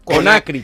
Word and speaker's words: Conakry: Conakry, Conakry.